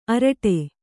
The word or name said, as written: ಆರಟ್ಟ